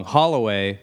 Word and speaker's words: Holloway: Yeah, and when people apologize for spelling my name wrong, Holloway,